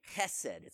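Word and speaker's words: Chesed (חסד): That word unfailing love is the Hebrew word hesed. You got to say it like like this, okay? Chesed, it's a strong H, chesed.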